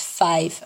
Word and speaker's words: FIWE: And my husband, who is not an English native speaker, came up with the acronym, which is FIWE and is pronounced five, according to him.